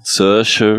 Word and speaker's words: Saoirse: Saoirse Ronan, mhm.